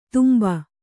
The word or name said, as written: ದುಂಬೆ